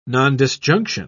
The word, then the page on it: nondisjunction: British English